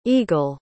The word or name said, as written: egil